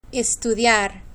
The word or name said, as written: estudiar